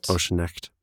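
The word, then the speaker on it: Ochsenknecht: I don't know how to say his name, but he's a German actor named Uwe Oschenskett? – Oschenecht. Yeah.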